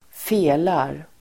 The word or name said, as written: felar